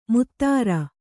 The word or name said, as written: ಮುತ್ತರ